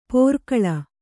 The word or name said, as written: ಪೋರ್ಕಳ